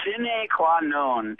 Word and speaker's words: sine qua non: Yeah, it's sine qua non. It's a Latin phrase, and that's the proper Latin pronunciation.